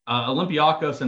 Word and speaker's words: Olympiacos: Is it Olympiacos? Is that how you say it? And then Marseille? – Olympiacos and Marseille.